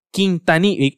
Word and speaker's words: Quintanilla: También terminé de ver y vamos a estudiar aquí terminamos de ver la, película de, o la serie de Selena Quintinilla. Quintanilla, y, lo tengo escrito y lo dije mal, de Selena Quintanilla.